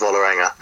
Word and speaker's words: Vålerenga: I'll get completely panned by my friends who are Norwegian, I'll speak Norwegian here, but the A with the little dot on the top of it is pronounced like an O, and I get that wrong completely. My housemate's probably laughing at me now listening outside. So it's kind of pronounced Vålerenga.